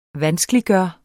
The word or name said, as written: VANSKELIGGJORT